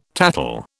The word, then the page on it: tattle: British English